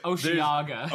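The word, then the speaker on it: Osheaga: Is it Oshawaga 2020 in Montreal? – Oh. – Okay, so. – Oceaga. – Oceaga.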